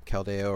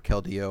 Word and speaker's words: Keldeo: So if you don't have Caldeo or Keldeo or however you want to pronounce it, now's your time to get it.